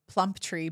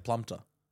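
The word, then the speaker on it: Plumptre: It's kind of like plump tree, but there's only one E at the end. – I see it like plumter. – That's incorrect.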